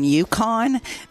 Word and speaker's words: Yukon: Okay, when we come back, we'll start in on our first questions about the senior games in Yukon. This is where you get to basically win. And then you will be eligible for the state games. Am I saying that right? – Yeah, okay.